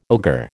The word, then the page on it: ogre: American English